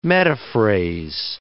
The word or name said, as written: metaphrase